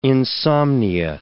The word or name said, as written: insomnia